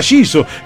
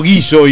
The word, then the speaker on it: Guiso: Lo traté mucho a Vitrola Giso. – Claro, se escribe igual. ¿Y por qué le decimos Guiso? Y a Vitrola le decimos Giso.